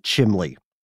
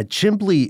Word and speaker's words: chimney: Yeah, chimbley is a legitimate sort of dialectical variation of chimney. But I didn't even have the B. I just thought it was chimley.